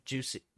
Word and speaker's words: Jussie: Dude, how do you say her his name? I think that's how Ugh. you say it. – Juicy, juicy, juicy.